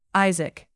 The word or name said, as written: Isaac